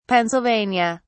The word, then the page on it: Pennsylvania: British English